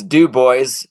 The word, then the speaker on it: Dubois: It's Dubois is how you say it in Wyoming.